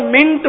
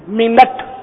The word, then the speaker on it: منٹ: آغاز جماعت کے آغاز جماعت عصر سے 26 منٹ قبل, ہمارے منٹ بولتے ہیں, 3 منٹ, 5 منٹ, یہ غلط تلفظ ہے, منٹ نہیں ہے, منٹ, نون پر زبر پڑا کریں.